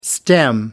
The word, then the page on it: stem: American English